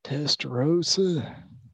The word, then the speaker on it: Testorrosa: Testarossa. – How do you spell it, sir? – TESTORROSA. – TESTORROSA.